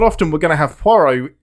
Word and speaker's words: Poirot: Parrot, it's Poirot.